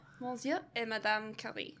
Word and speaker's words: Monsieur et Madame Curie: How do you say it for real? – Monsieur et Madame Curie. – Whatever. – You did it right.